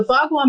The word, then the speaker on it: Bagua: Like the floor plan of your home could be the Bagua map. Am I saying that correctly? – Yeah, the Bagua map is really cool because you can layer it up.